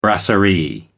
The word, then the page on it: brasserie: American English